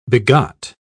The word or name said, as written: begot